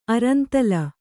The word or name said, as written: ಅರಂತಲ